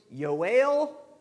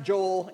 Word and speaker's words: Joel: Now, it is pronounced in the Old Testament Hebrew, Yoel, but please don't call me that, okay? It's pronounced Joel in New Testament days.